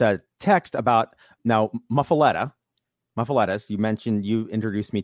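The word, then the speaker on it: muffuletta: They're called muffalettas, but he said the OGs are muffalettas. That's how you pronounce them.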